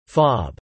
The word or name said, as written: fob